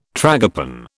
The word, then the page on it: tragopan: British English